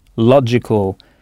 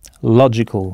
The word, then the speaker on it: logical: I-C-A-L. Alors, en partant de ce principe, comment dira-t-on logique ? – Logical. – Logical, avec le G qui se prononce J. Logical, bien.